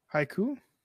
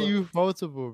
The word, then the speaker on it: Ufotable: Haiku? Not the haiku? – What the fuck is Ufotable? – Oh, you say Ufotable, bro. Not haiku.